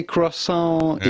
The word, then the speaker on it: croissant: The T at the end of croissant is definitely croissant. Silent, so croissant.